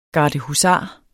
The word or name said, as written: gardehusar